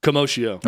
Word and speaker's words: commotio cordis: I've heard different pronunciations, by the way. I've heard two different doctors say commotio, like commotion, and then Dr. Montgomery, they said commotio cordis.